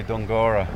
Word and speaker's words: Dunguaire: Um, this is called Dungura Castle. It's spelled Dunguaire, D-U-N-G-U-A-I-R-E, but all the locals say Dungora.